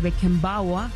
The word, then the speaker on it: Beckenbauer: Y por el otro lado, en el grupo B, la Alemania Federal de Beckenbauer, si está bien pronunciado.